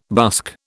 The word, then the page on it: busk: British English